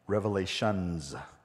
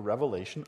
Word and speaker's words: Revelation: So Revelation, here's a really quick overview of the book of Revelation. First of all, it is pronounced Revelation, singular, not Revelations.